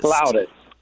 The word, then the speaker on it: flautist: Because I grew up being called or thinking I was a flutist, but I've also heard people say the correct pronunciation is flautist. – Flautist.